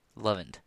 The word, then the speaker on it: LOVEINT: It's spelled L-O-V-E-I-N-T, Lovent, for Love Intelligence.